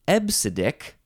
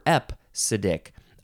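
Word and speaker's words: EBCDIC: EBCDIK, but you do pronounce it ebsidic. You can pronounce it ebsidic or epsidic. I don't know how the P sound got in there, because there's no P in all those letters. So ebsidic seems like the more correct way to say this.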